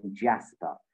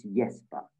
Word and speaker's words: Jesper: So she would always call him Jasper. And I kept saying to her as a 5 or 6 year old, I kept saying to her, it's Jesper, you know, knowing the sort of Danish pronunciation, I guess, because we know all these things as kids and as adults as well.